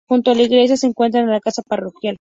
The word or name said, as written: iglesia